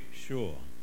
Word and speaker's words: sure: Madam, are you sure? Sure. Spelt with an S, but sounds like sh.